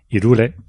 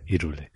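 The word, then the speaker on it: Hyrule: Eh, aquí entra en discrepancia eh, mucha gente porque hay algunos que le llaman Irule, como son los de mi generación, y otros que le llaman Irul por, eh, por su pronunciación en inglés, ¿de acuerdo? Así que yo, perdonadme, pero soy de, de los 80 y para mí se escribe Irule y se dice Irule.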